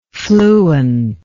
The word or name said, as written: fluentness